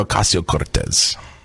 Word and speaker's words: Ocasio-Cortez: And then shortly right after that, you had your favorite Alexandria Ocasio-Cortez. Did I pronounce that properly? – Alexandria Ocasio-Cortez.